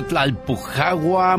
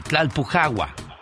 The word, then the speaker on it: Tlalpujagua: Un día salí de Tlalpujagua, Michoacán.